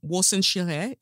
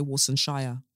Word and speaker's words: Warsan Shire: So currently I'm reading um Warsan Shire. Is that how you pronounce it? I'm reading her because I used to say Warsan Shire. So um, as in Yorkshire.